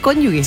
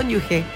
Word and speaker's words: cónyuge: Pero mucha gente dice cónyuge. – Se pronuncia como la J. Claro, claro, esa G suena como J. – Es cónyuge.